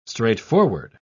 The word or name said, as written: straightforward